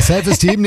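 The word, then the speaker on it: Self Esteem: Self-Eastern. – Ja, die Aussprache ist ein bisschen... – Steam, ist Steam. – Ja, ist richtig. – Ja, Self-Esteam nehmen wir.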